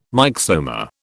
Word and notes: myxoma: British English

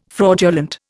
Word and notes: fraudulent: Indian

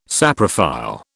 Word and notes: saprophile: British English

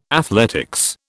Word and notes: athletics: British English

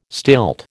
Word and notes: stilt: American English

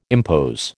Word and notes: impose: American English

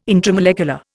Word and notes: intramolecular: Indian

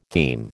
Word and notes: theme: American English

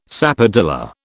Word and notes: sapodilla: British English

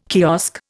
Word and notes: kiosk: Indian

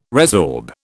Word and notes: resorb: British English

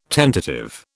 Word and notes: tentative: British English

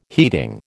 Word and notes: heating: American English

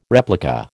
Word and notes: replica: American English

